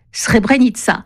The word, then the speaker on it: Srebrenica: L'armée de libération du Kosovo, qui s'écrit U-C-K, avec un signe diacritique en dessous du C, se prononçait Ucheka. Alors que la ville de Srebrenica, qui s'écrit bien avec un C, mais avec le signe diacritique au-dessus, se prononce Srebrenica. Signe en dessous du C, ça donne Tché. Signe au-dessus du C, ça donne Tse.